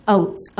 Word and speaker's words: Oates: My name is Janice Oates, O-A-T-E-S.